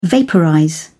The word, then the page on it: vaporize: British English